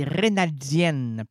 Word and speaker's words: raynaldienne: Là, les gens se, souvent me disent, c'est-tu rénaldienne? Ben non, c'est rénaldienne.